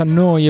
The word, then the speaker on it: Neu: si scrive Neu, si pronuncia Noi e vuol dire nuova, nuova emittente urbana.